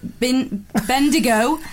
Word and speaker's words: Bendigo: My colleagues and I thought it was hilarious how you pronounced Bendigo. Less emphasis on the D for future reference.